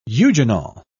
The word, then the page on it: eugenol: British English